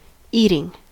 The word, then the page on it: eating: American English